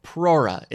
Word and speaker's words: Prora: That's the topic, Prora. It's spelled P-R-O-R-A.